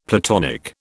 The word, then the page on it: Platonic: British English